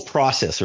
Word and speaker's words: process: You know, you say process in the Australia, so I'll do my best, but the whole process, right? Which again, I know because of Canada and what have you. That's right. That's right. That's the right way to say it.